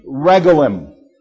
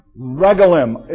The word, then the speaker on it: regalim: is one of three regalim. Now, this is probably an unusual word because I might be mispronouncing it. Regalim, it's, or regalim, R-E-G-A-L-I-M.